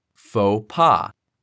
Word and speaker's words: faux pas: Instead, the O in faux makes an O sound and the X is silent. In pas, the A sounds like the A in the word caught, while the S is silent. Therefore, the term is pronounced like faux pas.